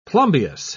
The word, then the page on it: plumbeous: British English